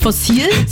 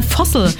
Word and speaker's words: Fossil: Beim letzten Mal kam raus, das heißt gar nicht Fossil, sondern Fossil.